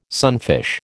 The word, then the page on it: sunfish: American English